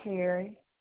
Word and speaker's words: Carey: Gabrielle Carey.